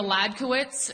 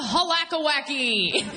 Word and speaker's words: Hledkowicz: Yeah, my name is Julia Ladquitz. It's spelled H-L-E-D-K-O-W-I-C-Z. Looks like an unsolved Wheel of Fortune puzzle. So that's good. A host the other day brought me the stage, and they're like, please welcome Julia Halakawacky.